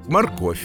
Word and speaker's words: морковь: В некоторых словах мы пишем букву «в», а произносим звук «ф» или «ф», если рядом есть мягкий знак. Например, в словах «морковь», «любовь» или «плов». В названии городов Саратов, Ростов.